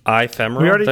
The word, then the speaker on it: ephemeral: He says we have the wrong pronunciation of ephemeral. Says the first letter is supposed to be pronounced as a short I.